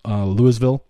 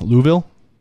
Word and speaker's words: Louisville: If I said that wrong, I apologize. Louisville. You sure it's not Louisville? – Anna, you're muted. – Take a crack at it. – No, he's got it. It's not Louisville. Louisville.